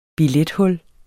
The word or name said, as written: billethul